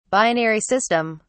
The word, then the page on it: binary system: British English